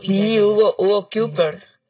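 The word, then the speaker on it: Cuypers: A ver, Kuipers, así se escribe. Es C-U-Y. – Kuipers.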